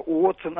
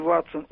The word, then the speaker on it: Уотсон: «уотсон», а не «ватсон», «уильямс», а не «вильямс», ну и так далее.